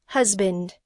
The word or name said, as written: husband